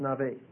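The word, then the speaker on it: nabi: It's the Hebrew word nave, N-A-B, long A. Pronounced nave.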